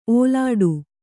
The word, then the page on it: ಓಲಾಡು: ōlāḍu